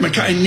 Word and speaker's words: MacKay Shields: Eric Kazatsky joins us with Mackay Shields. Am I pronouncing that right? – You got it. – Mackay nailed it.